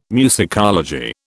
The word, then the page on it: musicology: British English